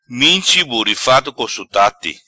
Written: Minchi burifado co sutate

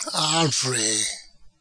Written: Arvre

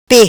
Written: Pe

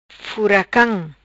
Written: Furacam

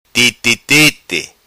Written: Titi Titi

